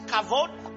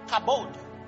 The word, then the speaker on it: kavod: The Hebrew word for glory, it's, it's spelled two ways. It's kavod, K-A-V-O-D or kabod. Kabod.